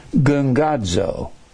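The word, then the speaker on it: gungadzo (γογγύζω): Two G's together is pronounced NG gungadzo.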